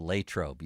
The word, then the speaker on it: Latrobe: You think they could fly out of Latrobe? – I like that you say it right. Mike DeFabo tomorrow will be proud. Latrobe, you said it.